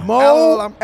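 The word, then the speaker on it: Mouallem: Mo, Mo Ellum.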